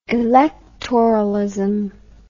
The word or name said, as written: electoralism